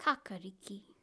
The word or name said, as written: kakariki